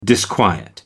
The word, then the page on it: disquiet: American English